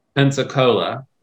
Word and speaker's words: Pensacola: I always put the stress in the wrong part of this pronunciation. Say it again. – My city? – Yeah. – Pensacola. – Pensacola.